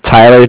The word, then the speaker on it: Tyler: Okay, my name is Tyler, T-Y-L-E-R, and I'm with Bank of Cardiff. It's spelled C-A-R-D-I-F-F, as in Frank Frank.